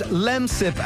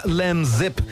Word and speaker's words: Lemsip: You discovered it's pronounced Lemsip and not Lemzip.